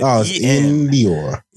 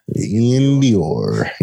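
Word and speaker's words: Iann Dior: Why you say it like it's two separate letters, man? It's one whole name. You said Ian Dior. – Ian Dior, yeah. – Ian Dior.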